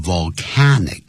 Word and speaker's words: volcanic: 这个念成 volcanic，可是火山的叫 volcano，这个声音发音就不一样了。 V-O-L-C-A-N-O Vol，你看我只要碰到 L 舌尖就往上翘了， volcano，好，那就是 one volcano， two volcanoes，是这样念的。